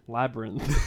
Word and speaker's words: Labyrinth: Labyrinth.